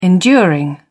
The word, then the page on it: enduring: British English